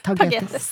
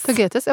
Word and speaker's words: tagetes: og også pulver av den ha hageblomsten som eh, mange har i hagen hver sommer, som heter tagetes. – Ja, jeg alltid lurer på hvordan man uttaler det. – Tage, tagetes. tagetes.